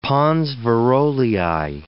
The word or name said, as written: pons Varolii